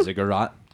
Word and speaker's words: ziggurat: But, the tower was, common thought is it's a ziggurat. I think I'm saying that right. It looks like juggernaut a little bit, but not actually like juggernaut. It's a ziggurat.